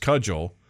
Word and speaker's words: CUDGEL: The Competitive Underground Jugger League, pronounced Cudgel, is in the American Midwest.